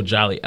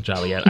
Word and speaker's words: Joliet: Oh, Joliet. I see that now. Joliet. I can't read. when all local folk know it's pronounced Joliet, made pronouncing it Joliet a misdemeanor, punishable by a $5 fine.